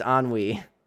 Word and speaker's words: ennui: It's ennui. – Ennui. That's that. I remember how because of how it's spelled. On, it's ennui. Ennui. – It's ennui. It is literally pronounced ennui.